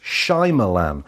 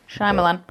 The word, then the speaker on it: Shyamalan: I think it's actually pronounced Shyamalan. But it's spelt Shyamalan. – Shyamalan. – I think he goes with Shyamalan.